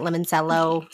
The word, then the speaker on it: limoncello: The only memory I ever have had of limoncello, is that how you say it? Limoncello something? – Cello. – It's limoncello.